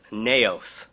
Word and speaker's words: naos: The second Greek word translated temple is naos, and it's spelled N-A-O-S, naos.